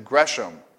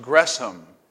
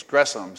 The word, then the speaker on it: Gresham: I mistakenly said Gresham, and there were hisses around the room from two people who knew that it's supposed to be pronounced Gresham. It's not S-H, it's Gresham.